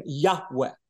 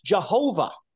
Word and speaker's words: Yahweh: And because language changed and the Y sound in Hebrew became changed to a J sound in English, Christians think that God's name is pronounced Jehovah.